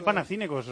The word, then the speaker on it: Panathinaikos: Pero se dice Panathinaikos, os lo digo en serio.